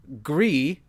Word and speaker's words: Gris: And the nominees are Red Dead Redemption 2, Gris. I presume that's pronounced Gris? – I was hoping that you knew. It's GRIS.